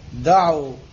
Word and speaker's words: دعوا: هاي ألف فارقة تأتي بعد واو الجماعة في الإملاء العربي تكتب ولا تلفظ دعوا سعوا واضح انتوا بسرعه تنباقون.